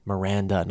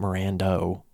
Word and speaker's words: Miranda: Miranda, not Mirando. Why do I always say Mirando? – Mirando.